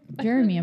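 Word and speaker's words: Jeremih: Jeremy, I'm pretty Jeremy? – Sure. – Jeremy? Jeremy? Yeah. – It's just spelled with an A. Different.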